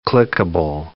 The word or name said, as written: clickable